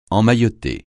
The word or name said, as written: emmailloter